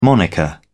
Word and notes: moniker: British English

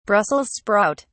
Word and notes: brussels sprout: British English

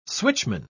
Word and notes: switchman: British English

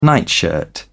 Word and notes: nightshirt: British English

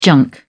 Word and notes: junk: British English

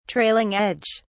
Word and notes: trailing edge: British English

